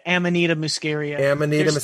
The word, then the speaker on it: Amanita muscaria: He's like, there, from what he can tell, I believe the soma in the ancient Indian scriptures was probably psilocybin or Amanita muscaria. Am I saying that right? – There's theory. Yeah. Amanita muscaria.